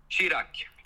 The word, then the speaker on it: Chirac: Säger du Chirac eller Chirac? – Chirac. – Ja.